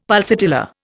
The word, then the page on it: pulsatilla: Indian